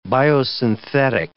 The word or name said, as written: biosynthetic